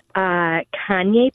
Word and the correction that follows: cayenne: pepper. Oh. Or, yes, I'm sorry, cayenne. – Cayenne pepper. – Cayenne. Pepper. – Uh-huh. I called it Kanye.